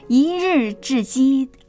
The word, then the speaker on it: 一日之计: 徐弟兄真的为您感恩，一日之计啊，这个读音要准确哈，虽然呢公鸡也是在早上叫的，一日之计在于晨，以早祷呢来展开新一天，真的非常的棒。